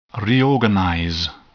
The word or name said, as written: reorganize